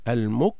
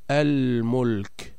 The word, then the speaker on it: الملك: بينما اللام الثانية هي لام الاسم، فلو حذفناها لم يستقم المعنى، كان نقول مثلا الملك، المك، يعني حذفنا اللام الثانية اللي هي لام الاسم، لا يصبح للكلمة معنى. لذا وجب علينا النطق بإظهار لام الاسم مع الفصل والتمييز بين اللامين، فنقول الملك، الملك، العلم، الخلق.